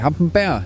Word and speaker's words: Hampenberg: En som dig, Rune Rasker, Hampenberg og Blæst. Jeg håber ikke, at jeg sagde Hampenberg. Hampenberg hedder det.